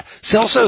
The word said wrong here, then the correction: Kelso: is going to be there. I mean, Kelso. I'm sorry. Why do I keep pronouncing that wrong? – Yeah.